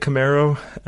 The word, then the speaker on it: Camaro: So is it Camaro? Is that how do you pronounce it? – I've been calling it Camaro. I think that's the way to pronounce it.